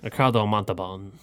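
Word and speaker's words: Montalbán: Ricardo Montalban.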